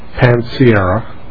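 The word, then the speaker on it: Panciera: Mr. Chairman, members of the committee, my name is Lawrence Pansiera.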